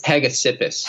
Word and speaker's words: Hegesippus: Is it, I'm not going to say this right, but is it Hegesippus or I think it's Hegesippus. – Hegesippus.